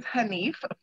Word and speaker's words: Hanif: Did I pronounce it right? I still can't pronounce it right. Hanif. Hanif. – My last name is Hanif, for those who know. Oh, my bad. Hanif. – Yes.